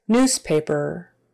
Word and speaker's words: newspaper: Newspaper, newspaper, newspaper. Newspaper. That middle S is like a Z. News.